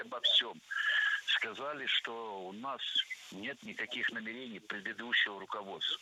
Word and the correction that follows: намерений: Намерений, намерения – это неверно. Правильно, намерение, намерений. Ударение в этом слове ставим на второй слог и не меняем это ударение при изменении слова.